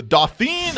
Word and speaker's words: Dauphin: The Queen of the Scots marries the Dauphin of France. – Dauphin. The Dauphin. – Dauphin? – Dauphin. – Dauphin. – Don't pronounce the N.